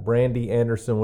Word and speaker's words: Brandy Anderson: We've got Brandy Anderson with us from Acceptance Recovery Center.